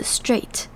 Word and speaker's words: straight: 最后我们来看这整句话的最后一个字 straight 这边要注意的地方是 AI 念成 A 长母音 A 的声音，而这边的 GH 跟刚刚的 throughout 一样，这边 GH 都是不发音的 straight。